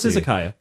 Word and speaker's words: izakaya: No, it's izakaya. – It's izakaya.